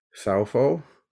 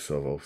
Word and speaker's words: Southall: Some people pronounce it Southall, but in my accent, I pronounce it Southall, so apologies if you don't pronounce it the same as me.